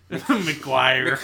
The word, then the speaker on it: Maguire: It's Jerry McGuire.